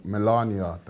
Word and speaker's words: Melania: Melania, that's how they say it.